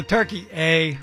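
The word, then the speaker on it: Türkiye: men's soccer team takes on Turkey A. – come on, with the Turkey A, really? – That's how Turkey wants us to pronounce it out here.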